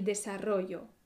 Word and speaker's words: desarrollo: Pero atención, porque la sílaba fuerte se desplaza en la palabra desarrollo. El desarrollo ta, ta, el desarrollo. Aquí la sílaba fuerte es ro.